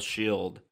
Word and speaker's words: S.H.I.E.L.D.: Agents of S-H-I-E-L-D. That's how you have to pronounce the title. You can't say it. – Shield. – Because it's got the periods in there.